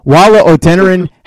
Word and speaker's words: Wola Odeniran: Wola Odeniran, how are you, Wola? – Good.